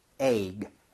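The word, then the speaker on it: egg: 그런데 발음 주의해 주세요. – Yeah. – 얘를 너무 이렇게 하면 마치 ek처럼 발음을 하는 격이 돼요. 그래서 그좀 부드럽게 가는 건데 저는 추천이요. 에익처럼 좀 늘어지게 하면은 비슷하게 발음을 할수 있거든요. 그렇다고 너무 대놓고 에이그 이렇게 하라는 게 아니라 부드럽게 에이그.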